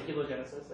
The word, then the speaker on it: hylogenesis: And we came up with a specific scheme that we call hylogenesis, right? I should probably say helogenesis, I think that's the correct pronunciation. Helo is apparently Greek for matter.